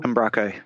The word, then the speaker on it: Umbraco: There's one that I'm not as familiar with, but it's one that I would definitely take a look at called, how do you pronounce it? Umbraco? – Umbraco, yep.